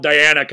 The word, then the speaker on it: Diana: It's hard because it really is spelled Diana conventionally, but it's Diana.